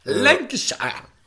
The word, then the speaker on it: Lancashire: Lancashire.